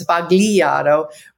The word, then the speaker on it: Sbagliato: And Spagli, I don't say it right, according to Carla, it's Sbagliato, but that means mistake in Italian.